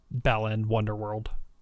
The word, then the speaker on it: Balan Wonderworld: I want to talk about Balan Wonderworld.